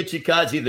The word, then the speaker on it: Chikadze: I think I said it right this time. – You did, yes. Giga Chikadze, the Georgian.